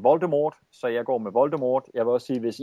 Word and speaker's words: Voldemort: Og derfor bliver det Voldemort i, i filmene, men tid skal altså ikke udtales.